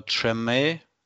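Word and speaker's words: Treme: Es gab äh, Tremay, wird es so ausgesprochen? – Ja, so, so genau so in etwa.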